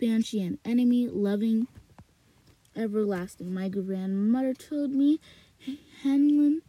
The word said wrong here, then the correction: Heinlein: Heinlein, Heinlein, I've been pronouncing that wrong.